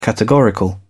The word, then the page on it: categorical: British English